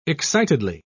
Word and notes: excitedly: British English

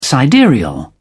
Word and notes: sidereal: British English

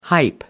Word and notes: hype: American English

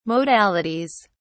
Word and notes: modalities: British English

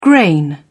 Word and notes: grain: British English

